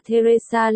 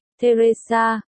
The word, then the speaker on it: Theresa: Tên là Theresa Lee, chữ T và H phát âm một lượt. Như Tim hay Therapy, Theresa, cô không phải người châu Á.